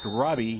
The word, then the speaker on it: Rabe: R-A-B-E, pronounced Robbie.